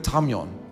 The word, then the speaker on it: tameion: The word closet is the Greek word tamion. Tamion. You spell that T-A-M-E-I-O-N. Tamion.